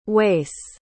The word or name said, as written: wace